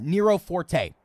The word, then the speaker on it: Nero Forte: The other day, I came across Slipknot performing, and I think that this is the correct way to say it, which I did it incorrectly in our audio album review of the album. Nero Forte.